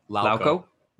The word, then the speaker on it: Lauko: How do you say his last name? – Lauko. – Yep.